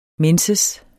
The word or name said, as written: menses